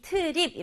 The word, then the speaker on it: trip: trip은 발음이 trip 이렇게 두 박자가 아니라 한 박자로 trip 이렇게 발음하는 게 중요해요.